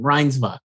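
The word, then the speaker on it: Reinsve: Then the jury picks were Renate, who actually, I think I had her, I phonetically spelled her name, Reinsva, I believe it is. I said it wrong before.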